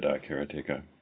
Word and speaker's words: karateka: When I was doing for Touch Arcade, I did a interviewed Mechner for the iOS version, and yeah, he said karateka. So, pointing out that there's no emphasis on any syllable, but I always said, and I still say karateka.